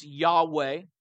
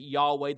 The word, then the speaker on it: Yahweh: That's the covenant name of God, sometimes pronounced Yahweh, translated Lord, but the Hebrew words or letters are YHWH, and so some pronounce it Yahweh, the Latin is Jehovah.